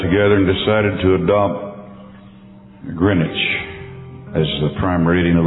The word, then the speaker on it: Greenwich: Our prime meridian in the world is located in Greenwich. That's how they pronounce it. It's spelled Greenwich, right out of London.